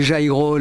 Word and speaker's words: Jairo: Bueno, hasta que se aclaró la cosa y bueno, la J se pronunció como G, como pronuncian ellos, y Jairo resultó mucho más fácil.